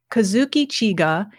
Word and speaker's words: Kazuki Chika: It is an English translation, your English translation, of the book written by a Japanese man named Kazuki Chika. – Yeah. – Am I pronouncing that correctly? – Yeah, correct. – Kazuki Chika.